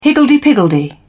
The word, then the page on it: higgledy piggledy: British English